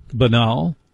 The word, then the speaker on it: banal: That may sound banal, and please note I pronounced it correctly.